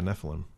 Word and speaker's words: Nephilim: How do you pronounce that? N-E-P-H-I-L-I-M. – Yeah, Nephilim. Well, I played enough Diablo to know it's pronounced Nephilim. – Nephilim sounds about right to me.